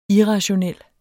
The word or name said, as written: irrationel